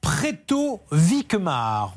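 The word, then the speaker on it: Pretot-Vicquemare: Alors, il est à Préto-Vicmar. On prononce comme ça Tout à fait.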